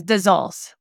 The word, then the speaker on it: Dazols: It's Dazols.